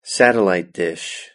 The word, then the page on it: satellite dish: British English